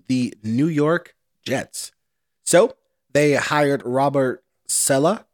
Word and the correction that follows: Saleh: I think that's how you say his name, and Saleh. Saleh.